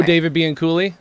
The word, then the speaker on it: Bianculli: I haven't seen the documentary, but I've read I don't know how to pronounce his name, but the Bianucci? – Oh, David Bianculli, if I remember correctly. – There it is.